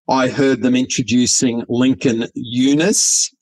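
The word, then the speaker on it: Younes: But on the other night, I heard that the correct pronunciation for our great Australian actor is Lincoln Yunus.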